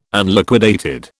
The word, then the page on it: Unliquidated: British English